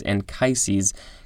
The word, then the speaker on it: Anchises: I think that's how it's pronounced. I wanted to say anchises, but the pronunciation guide is telling me it's anchises. Capital A-N-C-H-I-S-E-S. This is a noun from the 14th century. The father of Aeneas, A-E-N-E-A-S, rescued by his son from the burning city of Troy.